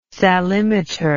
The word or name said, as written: salimeter